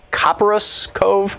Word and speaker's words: Copperas Cove: Brenda in Copperas Cove, Texas.